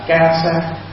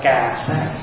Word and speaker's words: cà sa: Chữ y là tiếng Ấn, tiếng của mình, mà nói đúng tiếng Phạn là ca sa, hay đọc là cà sa.